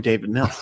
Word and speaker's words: Mills: This offense is going to go through David Mills. So I didn't get his name right. It's Davis, isn't it? – No, you nailed it.